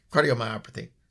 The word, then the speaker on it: cardiomyopathy: Yeah, cardiomyopathy.